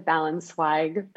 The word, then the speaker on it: Ballensweig: I hope I'm getting your name right. – Yeah, that's pretty good. I pronounce it Balanswig, but you are very, very, very close.